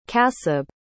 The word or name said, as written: kasib